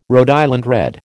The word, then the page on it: Rhode Island Red: American English